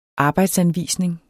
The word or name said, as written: arbejdsanvisning